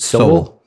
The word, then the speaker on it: Sowell: Thomas, how do you pronounce it? – Sowell. – Yeah, that's what I thought too.